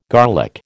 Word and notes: garlic: American English

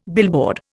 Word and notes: billboard: Indian

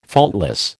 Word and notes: faultless: American English

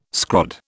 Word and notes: scrod: British English

